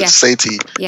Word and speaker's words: Sethi: One of the things that Ramit said, and I still don't know how to pronounce it, Sethi. – Yeah.